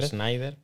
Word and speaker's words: Snyder: Zax, no sé si es Sinder o Snyder o cómo se pronuncia, ya lo hemos hablado. Yo digo Snyder. – Pues venga, vamos a decir Snyder.